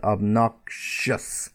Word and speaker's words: obnoxious: Or obnox, again, the K sound, obnoxious, the obnoxious man, the obnoxious letter, the obnoxious person, okay? Obnoxious, okay?